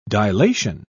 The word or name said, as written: dilation